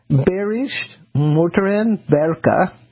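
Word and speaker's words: Bayerische Motoren Werke: Okay, in 1918, Berish Motoren Berka, we've got a lot of W's pronounced as V's today, A-G, which is, to those of us who are not German, BMW, established as a public company in Germany in 1918.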